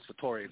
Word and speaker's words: Vittori: But I will just say, for all the voters out there, the double T, my last name is Vittori. It's Italian.